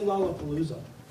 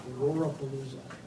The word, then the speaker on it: Lollapalooza: If the first two syllables came back as Rora, they were ordered to open fire, shoot on sight without hesitation. Don't even complete the word Palooza. Say Lollapalooza. Rora Palooza. Done.